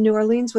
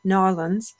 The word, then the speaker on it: New Orleans: I didn't say that right. New Orleans. With her husband, Derek, and sons, Sam and Grant. I have been schooled, but, you know, the wrong pronunciation slips out.